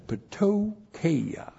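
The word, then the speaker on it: ptocheia: Conjunction chi is connected chi, translated and then patokeia. PT, pastor teacher or potty trained, one or the other. PT, long O, C, H, E, I, A.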